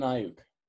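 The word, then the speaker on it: Aiyuk: Brandon Ayuk? – Yeah, that's correct. It's Brandon Ayuk.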